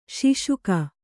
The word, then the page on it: ಶೋಷಕ: Kannada